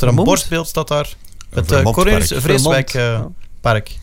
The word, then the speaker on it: Cornelius Vreeswijk: En dat is, kunnen wij misschien eens aan Thomas vragen hoe men in Zweden zijn naam zou uitspreken? – Cornelius Vreeswijk. De Zweden zijn ook wel correct met het uitspreken van Nederlandse namen.